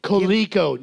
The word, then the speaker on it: Coleco: What human name did Colco give to its 1983 home computer? What human name did Colco... – Coleco. Not everything that has your first fucking name in it is pronounced like that.